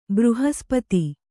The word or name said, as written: ಬೃಹಸ್ಪತಿ